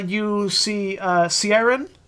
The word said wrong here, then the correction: Kieran: and Osen. – Like, totally mangled pronunciation, but sure, that's good too. – Yeah, that's as far as I can pronounce it. – Yeah, it's Kieran and Ocean.